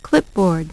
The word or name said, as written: clipboard